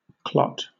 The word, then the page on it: clot: British English